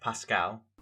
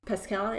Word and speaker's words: Pascal: So we did a little bit of Pascal. Is that how you pronounce it? – Pascal. – Pascal, yeah.